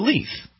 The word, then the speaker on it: Leith: Leith. Leith. I like Leith. Leith. – How's it spelled? – L-E-I-T-H.